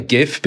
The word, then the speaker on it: GIF: I say GIF because I'm a hard G man, but you know.